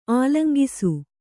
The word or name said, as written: ಆಲಂಗಿಸು